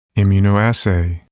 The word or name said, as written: immunoassay